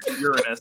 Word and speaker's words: Uranus: So, Uranus is technically how you're supposed to pronounce it.